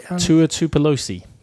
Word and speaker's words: Tua Tagovailoa: Can you pronounce the quarterback that came on and played? – Tua Tagovailoa. – Yeah.